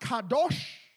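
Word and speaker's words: kadosh (קדוש): In other words, the word holy in Hebrew is pronounced kadosh.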